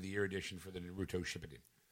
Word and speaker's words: Naruto: Neuro Shippuden Ultimate Ninja Storm Legacy by Bandai Namco Entertainment America Incorporated for the PS4 and Xbox One. And that's a Friday release. – Naruto.